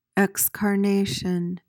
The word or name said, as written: excarnation